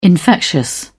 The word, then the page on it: infectious: British English